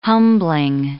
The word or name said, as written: humbling